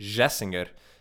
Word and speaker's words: Gessinger: It's His name is Umberto Gessinger, and it's G-E-S-S-I-N-G-E-R. And even though the conventions of Portuguese pronunciation would make it Gessinger, it's pronounced Gessinger.